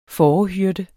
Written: fårehyrde

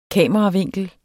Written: kameravinkel

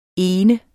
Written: ene

